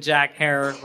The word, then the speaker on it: Herer: You have been replaced by none other than Jack Herer. Rhymes with terror. Rhymes with terror for sure.